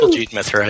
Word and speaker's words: Kuljit Mithra: Kuljit Mithra, yes, that's fine.